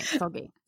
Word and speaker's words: Seulgi: And then we're going to talk about Red Velvet, Irene and Seulgi. Am I saying that right? Yeah. You pronounce things so much better than me. – I believe that's right, Seulgi.